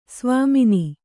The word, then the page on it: ಸ್ವಾಮಿನಿ: svāmini